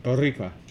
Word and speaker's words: Boricua: She's totally Boricua. – That's how you pronounce that. – Boricua.